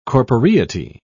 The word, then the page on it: corporeity: British English